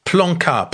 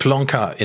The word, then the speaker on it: Plan K: called Plonka, Plan K, pronounced Plonka in French.